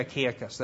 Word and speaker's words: Achaicus: Yeah, in 1 Corinthians 16:15, it would have been Stephanus, Achaia, and then Fortunatus. I think that's how you would pronounce it. And I'm sorry, Achaicus, that's what it is.